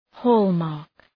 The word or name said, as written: hallmark